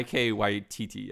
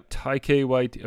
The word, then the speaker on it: Taika Waititi: Okay, so going back to Flight of the Conchords, this week, what is his name, the director, Ty Waititi or whatever, from New Zealand? – Yeah, Taika Waititi, yeah. – Taika Waititi, okay.